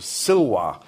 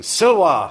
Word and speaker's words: Silva: So my dad's nickname at school, some of you will know this, was Silva, Latin for wood or forest, except that in the English tradition, you didn't pronounce the V's. I don't know if they still do that in the States. So you pronounce the V's as W's. So in fact, his nickname was Silwa.